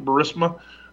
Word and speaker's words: Burisma: Is that how you pronounce it, Burisma? Gas company over there in Ukraine? Yep.